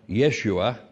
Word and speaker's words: Yeshua: So, on the question of this name, what you need to know, and some would already know, is that the name Jesus, but in the local idiom of, you know, Aramaic, would have been pronounced Yeshua, which actually is Joshua. So the Old Testament name Joshua was pronounced, as far as we know, Yeshua, and this was the name given to Jesus.